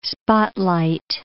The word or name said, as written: spotlight